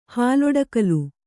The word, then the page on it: ಹಾಲೊಡಕಲು: Kannada